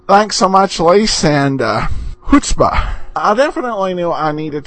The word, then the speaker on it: chutzpah: Hearing you say Poirot perfectly the other day reminded me to send you the correct pronunciation of chutzpah. It is chutzpah. The CH is pronounced like the H, and I know I've probably said chutzpah a few times.